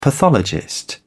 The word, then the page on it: pathologist: British English